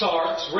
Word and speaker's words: sarx: This word flesh comes from a Greek word that is pronounced sarx, rendered as flesh, carnal, carnally minded, and fleshly.